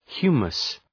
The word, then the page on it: humus: ‘hju:məs